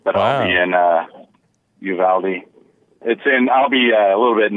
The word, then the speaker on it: Uvalde: Yeah, Uvalde, yeah.